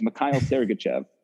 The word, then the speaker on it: Mikhail Sergachev: So I don't know if that's just the American way that said it, but all I know is that the NHL pronunciation guide has Mikhail Sergachev.